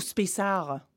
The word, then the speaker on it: Space Art: Space Art ou Space Art? – Space Art. – Voilà, j'en étais sûre qu'il fallait prononcer le T, mais je veux faire comme une bonne américaine.